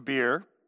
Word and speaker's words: Be'er: Verse 21, then Jotham fled, escaping to Beer. It's a great place to escape to, If I was pronouncing it, I should say Be'er, but it says Beer, so I'm just going to say it.